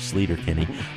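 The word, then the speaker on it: Sleater-Kinney: This apparently is the correct pronunciation for the off-ramp in Olympia on the highway that originally gave the group its name. We've been saying it wrong for 20 years, Slater-Kinney.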